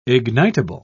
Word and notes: ignitable: British English

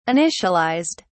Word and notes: initialized: British English

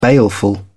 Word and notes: baleful: British English

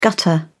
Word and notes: gutter: British English

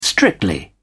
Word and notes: strictly: British English

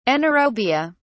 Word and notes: anaerobia: British English

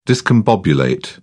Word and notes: discombobulate: British English

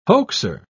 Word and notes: hoaxer: British English

